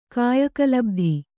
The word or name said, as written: ಕಾಯಕಲಬ್ಧಿ